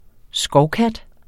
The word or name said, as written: skovkat